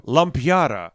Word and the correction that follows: Lumpiwaru: No, it's Lumpiwaru. – I think that's actually correct.